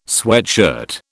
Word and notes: sweatshirt: British English

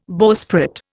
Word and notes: bowsprit: Indian

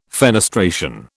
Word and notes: fenestration: British English

